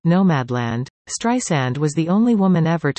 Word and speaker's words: Streisand: After famously calling Apple CEO Tim Cook to get Siri to pronounce her last name right, no Z sound, Streisand learned to override auto-correction with some advice from her assistant.